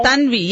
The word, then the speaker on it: Tanvi: T-A-N-V-I. – Oh, Tanvi.